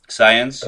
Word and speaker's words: Saenz: Saenz, Recio.